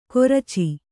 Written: ಕೊರಚಿ

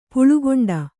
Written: ಪುಳ್ಕೊಂಡ

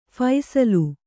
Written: ಫಸಲು

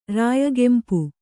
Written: ರಾಯಗೆಂಪು

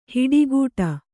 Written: ಹಿಟ್ಟುಗುಟ್ಟು

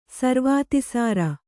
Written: ಸರ್ವಾತಿಸಾರ

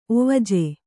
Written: ಒವಜ

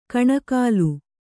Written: ಕಣೆಕಾಲ್

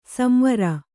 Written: ಸಂವರ

